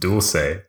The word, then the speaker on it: dulce: It's spelled and pronounced dulce. – Oh, yeah. – Because it's a Latin American treat. It's not Italian.